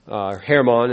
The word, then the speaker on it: Hermon: Hermon, as they pronounce it over there.